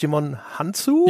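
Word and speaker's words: Djimon Hounsou: Äh, Jimon Hunt, wie spricht man den aus? Jimon Hanzu? Ja, so ungefähr.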